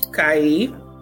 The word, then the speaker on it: Kai: Kai.